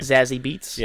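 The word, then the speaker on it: Zazie Beetz: Zazie Beats.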